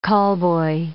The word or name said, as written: callboy